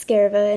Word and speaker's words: Skarva: It's not Skarva, it's Skarva, and it actually comes from a Swedish word, which means shard or fragment.